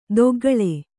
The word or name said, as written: ದೊಗ್ಗಳೆ